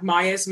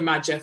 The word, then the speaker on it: Maja: She's coming around to my house tonight because we're going flat tracking together tomorrow, and you can find her online, like Maya's Motorcycle Adventures. Her name is spelled M-A-J-A, so I called her Madja for ages, but it's actually Maya.